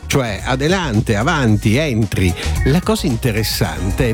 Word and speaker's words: adelante: La pronuncia spagnola è circa adelante, con accento sulla seconda A.